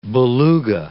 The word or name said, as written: beluga